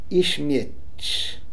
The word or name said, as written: Ischmetch